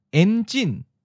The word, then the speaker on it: enjin: So here's an example of a two-syllable long word, enjin. En is in the ninth tone, and jin is in the second tone, high falling.